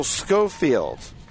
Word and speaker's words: Schofield: So strikeout number three for Chun, and up next is Mitchell Schofield. So they have more of a hard C on that. We got some information. So it's Mitchell Schofield with one away.